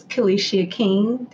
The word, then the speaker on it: Kelishia King: My Instagram name is Kelishia King. That is spelled K-E-L-I-S-H-I-A-K-I-N-G.